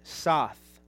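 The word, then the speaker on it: soth (סוד): And so friendship is the Hebrew word soth, soth. And so how to spell that is S-O-T-H. That's the Hebrew word for friendship, S-O-T-H, soth.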